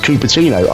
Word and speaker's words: Cupertino: Yeah, I laughed when I saw it, because even though it is pronounced Cupertino, I checked, it looks exactly like cup of tea.